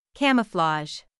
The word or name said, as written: camouflage